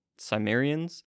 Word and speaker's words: Cimmerians: Yeah, and even where you have um, the allies to Babylon were the Elamites, the Medes, the Scythians, and I think it's pronounced the Cimmerians. – Um, Probably better than what I would say. Yeah. Well, it's, it's spelled C I M M E R I.